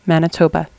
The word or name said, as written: manitoba